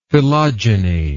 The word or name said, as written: philogyny